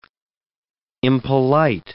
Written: impolite

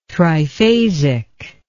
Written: triphasic

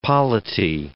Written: polity

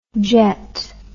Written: jeth

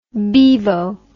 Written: beevo

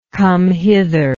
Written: come-hitherness

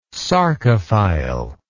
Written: sarcophile

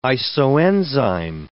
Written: isoenzyme

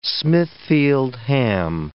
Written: Smithfield ham

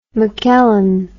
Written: mckellen

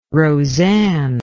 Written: rosanne

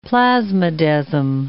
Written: plasmodesm

